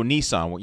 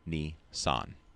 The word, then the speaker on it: Nissan: But Nissan only uses the number 23 on their vehicles, since in Japanese, the number 2 is pronounced as Ni and 3 as San. Together, this gives Nissan.